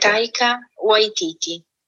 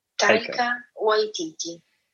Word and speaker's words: Taika Waititi: Taika Waititi. Taika Waititi.